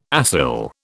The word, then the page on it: acyl: British English